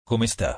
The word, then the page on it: Come sta: Italian